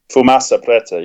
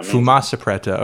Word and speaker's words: Fumaça Preta: Tell me if I'm pronouncing the name correctly. Fumaça Preta? – Fumaça Preta, yeah. – Fumaça Preta.